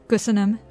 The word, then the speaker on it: Köszönöm: How do you say thank you in Hungarian? – Köszönöm. – Köszönöm. Köszönöm.